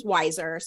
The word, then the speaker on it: Weiser: Yeah, my father told me many years ago, my last name's Weiser, spelled W-E-I-S-E-R.